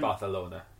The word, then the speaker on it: Barcelona: It's pronounced Barcelona. – It's pronounced Barcelona if you're a dick.